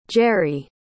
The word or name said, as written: gerri